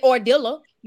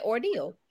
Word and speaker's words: ordeal: exactly you won't say ordilla you'll say ordeal oh shit you put extra syllables in there